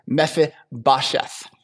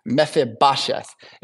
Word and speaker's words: Mephibosheth: It's Mephibosheth. Mephibosheth.